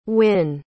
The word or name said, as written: wynn